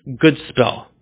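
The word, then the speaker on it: gōdspel: It was written like Godspell, pronounced good spell.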